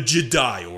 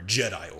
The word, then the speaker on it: Jedi: Not the Jedi Order, Jedi Order. – Yes. – Now I could go back to saying the proper Jedi instead of doing the emphasis on the die.